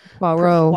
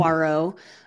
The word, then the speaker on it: Poirot: Hercule Poirot. – Poirot.